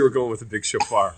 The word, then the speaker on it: shofar: So, because I looked down at his travel shofar. Shofar, is that how you say it? – Yeah, shofar.